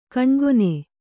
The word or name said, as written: ಘನಾಘನ